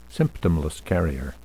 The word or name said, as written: symptomless carrier